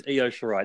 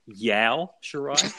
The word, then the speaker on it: Io Shirai: So her name is Io Shirai. It's spelled I and an O. I have heard people say Yao Shirai. I don't know how they got I-O. It was pronounced Yao.